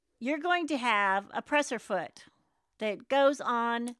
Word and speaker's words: presser foot: It is a presser foot. It's how it's spelt and it's how it's said.